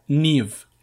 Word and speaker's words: Niamh: Y su nombre se escribe NIAMH, ¿vale? ¿Cómo se pronuncia? ¿Cómo se pronuncia? No, no. – Se pronuncia David. – No, obviamente no. Se pronuncia Niv.